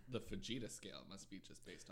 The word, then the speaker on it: Fujita: Fujita scale. I always thought that this was based... – It's pronounced fajita. I can't stand it. – No, fajita has an A. This is a U. Fujita.